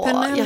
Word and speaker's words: Penelope: Åh, Penelope. Och jag bara, vet du, vi kallar henne för Poppy. Jag tyckte inte det var så för ni vill ha engelskt uttal. – Ja, Penelope.